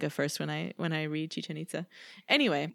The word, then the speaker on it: Chichen Itza: And so one of these was like, this famous ancient city rhymes with chicken pizza. – Jeez. – And the answer was Chichen Itza.